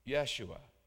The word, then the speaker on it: Yeshua: Salvation in the Hebrew is pronounced Yeshua.